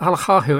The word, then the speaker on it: Alakarhu: Am I pronounced your surname right? – So Finnish way would be saying Juha Alakarhu. But I think you are pretty good. – Okay. I need to roll my R's more then.